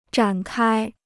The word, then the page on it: 展开: zhǎn kāi